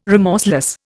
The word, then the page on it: remorseless: Indian